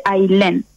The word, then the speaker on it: Ailén: ¿Se escribe A-Y-L-E-N? – No, mi nombre es uno de los pocos que conozco que se escriben A-I-L-E-N. Se escribe Ailén y se dice Ailén.